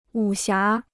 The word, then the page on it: 武侠: wǔ xiá